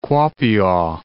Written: Kuopio

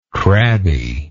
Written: crabby

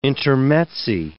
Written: intermezzi

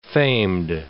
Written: famed

